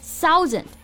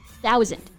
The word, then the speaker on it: thousand: So I hear some of you may pronounce it as thousand. No, it's thousand. – 没错，th 这个字母组合呢，我们要轻咬舌尖，不要发成了 s 的音。